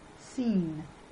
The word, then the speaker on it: scene: C is usually silent after S and before E or I. Before A, O, U, and consonants, it is pronounced as K. Scene.